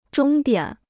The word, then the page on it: 终点: zhōng diǎn